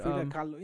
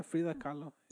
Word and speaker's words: Frida Kahlo: How do you think I pronounced her name wrong? My bad. – Frida Kahlo. Yeah, Frida Kahlo.